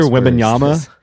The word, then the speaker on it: Wembanyama: Victor Wembanyama.